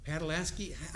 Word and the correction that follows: Pataskala: I always forget how you say it. Where am I? Pataskala. I ain't from around here. That was not for show. I butchered that all the time.